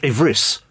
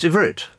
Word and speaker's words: Ivrit: And so Ivrit, it's not Ivris, it's Ivrit.